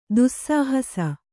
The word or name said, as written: ದೋಸ್ಸಾಹಸ